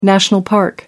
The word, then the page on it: national park: American English